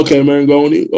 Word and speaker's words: Marangoni: So when you started fashion and you graduating, now probably going to say this wrong, the Instituto Marangoni. – Marangoni.